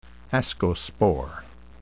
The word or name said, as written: ascospore